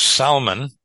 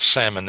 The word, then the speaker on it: Salmon: I think Matthew in 1, I think that in verse 5, it says, Salmon, that's spelled not salmon that you eat, Salmon begot Boaz by Rahab.